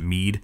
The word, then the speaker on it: Mid: It's spelled M-I-D, but they pronounce it Mead, so I feel like I should pronounce Cid Seed every now, from now on, and I really get it.